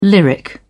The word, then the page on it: lyric: British English